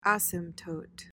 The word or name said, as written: asymptote